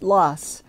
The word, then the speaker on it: Laas: Their last name is spelled L-A-A-S and pronounced Loss.